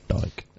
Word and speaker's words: Dyck: It's by this guy called Peter Van Dyck or Dyke. I don't know how you pronounce it. – Dyke. – Dyke. – Peter Van. – Okay. It's spelt Dyck. Dyck.